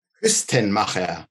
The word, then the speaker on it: Kuestenmacher: Kuestenmacher.